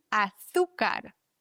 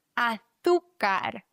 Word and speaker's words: azúcar: Achtung, azúcar hat ganz viele Ausspracheregeln in einem Wort. Einmal das Z, das müssen wir lispeln im Castellano. Azúcar. Dann haben wir auf dem U noch einen Akzent, das heißt, die Silbe wird betont. Azúcar. Und dann haben wir noch ein C vor A. C vor A, O und U wird ausgesprochen wie ein K.